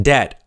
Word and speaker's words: debt: Debt means you owe money. Debt, D-E-B-T. But the B is silent. B-b-b. Don't say debit. It's debt.